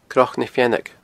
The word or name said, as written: cnocnafeannaig